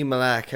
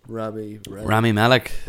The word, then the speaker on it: Malek: But you might know too, like Rami Malek. How do I say his name? Rami Malek.